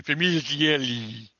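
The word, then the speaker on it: Famiglietti: Scott Peterson was played by Mark Famigliati. – That's how you pronounce it in Italian. Famigliati.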